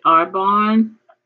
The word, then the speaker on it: Arbonne: Well, you mentioned a few minutes ago in your introduction how you also share meal plans, and you mentioned Arbonne.